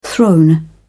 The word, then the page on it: throne: British English